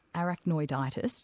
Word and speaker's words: arachnoiditis: Well, Catherine, you have arachnoiditis. Did I say that right? – Yes, correct.